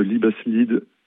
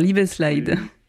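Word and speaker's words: Liebesleid: Et ce Liebeslied, ça veut dire chagrin d'amour. – Ah, j'avais mal prononcé. Alors Liebeslied.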